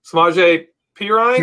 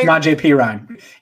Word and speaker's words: Samaje Perine: And if he isn't, are we going to see Giovanni and Bernard and uh, I can't even say his name, Samaje Perine? – Samaje Perine.